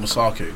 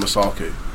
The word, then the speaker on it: Masaku: When it came out, obviously my name is Daniel Masaku, or Dan Masaku, and it vaguely, loosely rhymes with. He's just a rascal.